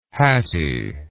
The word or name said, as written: hatty